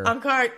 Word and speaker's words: Ankara: Ankara game crazy out here. – Ankara, thank you. And you said it right.